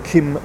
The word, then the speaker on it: Quim: He's called Kim Monzo. In English, there's a problem with Kim because it's spelled Q-U-I-M. But it's Monzo, M-O-N-Z-O.